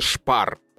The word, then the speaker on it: Spar: Допустим, а, буква сочетания СТ в начале слова читается как ШТ. То есть, допустим, Spar, а, вот э, в России сейчас есть такая марка, а, магазины Spar, это немецкая же компания, и правильно читается Шпар, потому что СТ, СП, вот это вот.